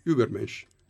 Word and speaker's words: Übermensch: Ma la pronuncia è corretta? – Uber è un po' caricatura. – Come sarebbe? – Più o meno. Beh, Übermensch.